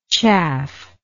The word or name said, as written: chafflike